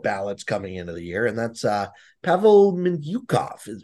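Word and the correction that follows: Mintyukov: Am I saying that right? – Minchukov. – There we go.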